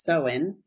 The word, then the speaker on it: Samhain: And I read that it is, it comes from the Celtic festival called Samhain, definitely spelled Samhain, but it is pronounced Samhain.